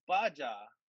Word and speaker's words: Baja: No, no, it's Ja. It's Baja. – Yeah, but it's pronounced Ha, not Ja.